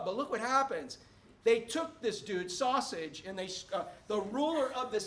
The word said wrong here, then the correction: Sosthenes: All right, so how do you say it, Emily? Sosthenes, okay.